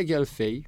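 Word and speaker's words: Gaël Faye: Yeah, Gail Faye.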